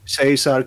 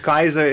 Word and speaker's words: Caesar: Eh, Augustus die vocht samen met Marcus Antonius, eh, een van de uh, luitenanten van het, waarschijnlijk de belangrijkste luitenant van Julius Keizer. – Mhm. – Heerlijk hè, zou dat switchen. Ja, ja. Caesar, Keizer. Het klopt allebei, hè? Want natuurlijk, Keizer is dan de echte.